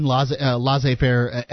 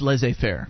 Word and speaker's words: laissez-faire: We don't live in laissez-faire economic situation. – I believe it is pronounced laissez-faire.